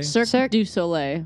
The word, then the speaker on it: Cirque du Soleil: One man Czech version of Circus Olay. – Okay. – Circus Olay? Circus Soleil? – Cirque du Soleil.